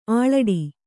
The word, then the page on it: ಆಳಿಡು: āḷiḍu